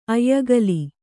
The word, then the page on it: ಆಯಗಾಲು: āyagālu